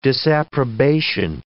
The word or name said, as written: disapprobation